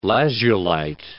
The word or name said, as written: lazulite